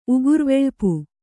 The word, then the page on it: ಉಗುರ್ವೆಳ್ಪು: Kannada